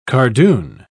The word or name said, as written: cardoon